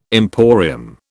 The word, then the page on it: emporium: British English